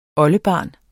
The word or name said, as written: oldebarn